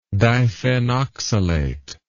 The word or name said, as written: diphenoxylate